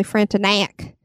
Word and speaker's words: Frontenac: The Chateau Frontenac. – I think it's actually Frontenac. – Frontenac? – Well, I mean, the pronunciation guide was more knack than knock, but I want to say knock too.